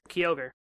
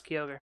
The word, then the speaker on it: Kyogre: I got called out for how I pronounce Kyogre. – Why? – Apparently, they had never heard anyone pronounce Kyogre instead of Kyogre. It's like everyone's like Kyogre. I'm like, there's no A in there.